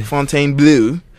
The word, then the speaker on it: Fontainebleau: Fontainebleau.